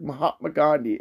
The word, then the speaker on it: Mahatma Gandhi: I can't say his name. I don't know why. It's hard. Mahatma Gandhi. there we go.